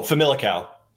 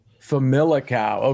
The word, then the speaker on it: Famalicão: Oh, Famalicão. – Famalicão.